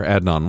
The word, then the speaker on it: Adnan: A classmate at Woodlawn High School says she knows where Adnan, what did I say? I think I mispronounced his name the first time, where Adnan was.